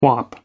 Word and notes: whop: American English

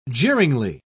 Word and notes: jeeringly: British English

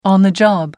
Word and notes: on-the-job: American English

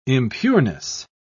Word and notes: impureness: British English

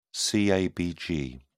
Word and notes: cabg: British English

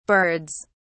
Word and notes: birds: British English

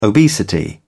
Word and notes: obesity: British English

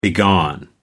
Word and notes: begone: American English

